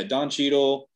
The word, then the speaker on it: Don Cheadle: Yeah, Don Cheadle and Chris Hemsworth and her.